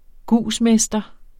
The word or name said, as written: gusmester